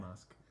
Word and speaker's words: Meuschke: It's spelled like M-U-S-C-K-H-U. – M-E-U-S-C-H-K-E. – Yeah, so it's not perfect, but yeah. Phonetically it's yeah. Musk.